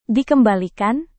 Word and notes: dikembalikan: Indonesian

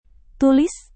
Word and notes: tulis: indonesian

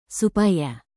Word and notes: supaya: Indonesian

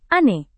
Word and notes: ane: indonesian

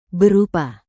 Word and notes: berupa: indonesian

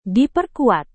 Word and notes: diperkuat: Indonesian

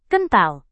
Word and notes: kental: indonesian

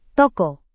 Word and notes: toko: Indonesian

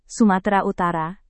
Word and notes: sumatera utara: Indonesian

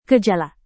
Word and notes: gejala: Indonesian

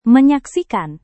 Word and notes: menyaksikan: Indonesian

